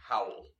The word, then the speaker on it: Howell: Sure, Howell.